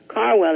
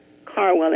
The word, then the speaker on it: Carwell: Carwell is my maiden name. Carwell, and it's spelled C-A-R-W-E-L-L. And I think it used to be Caldwell, C-A-L-D-W-E-L-L.